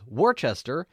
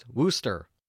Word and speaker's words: Worcester: I have been informed that the town I mistakenly pronounced as Worcester is in fact pronounced Wooster.